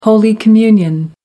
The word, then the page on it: holy communion: American English